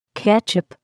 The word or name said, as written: ketchup